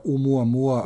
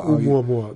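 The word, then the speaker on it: Umuamua (ʻOumuamua): Zatim tu imamo kako bih rekao, prvo telo koje smo detektovali da prolazi, van solarno telo koje prolazi kroz sunčev sistem, Umamumamu ili kako se zove. – Mislim da se izgovara Umuamua. – Umuamua, da.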